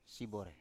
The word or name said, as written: sibore